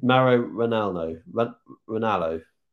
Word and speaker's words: Mauro Ranallo: Mauro Ranallo.